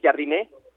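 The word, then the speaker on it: Jardine: que ya pudimos confirmar, esa es como la pronunciación correcta del Jarriné, entrenador brasileño del San Luis, Jarriné.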